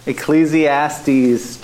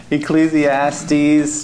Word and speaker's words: Ecclesiastes: Ecclesiastes chapter 9. Verse 7. Did I say it right? – Yeah. – Good, thank you. Yeah. Ecclesiastes 9:7.